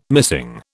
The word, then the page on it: missing: British English